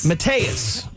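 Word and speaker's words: Mattias: Lieutenant Mattis? Is that how you say it? – Mateus.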